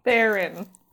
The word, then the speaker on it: Theron: Theron. Rhymes with Heron.